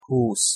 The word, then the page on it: husz: Hungarian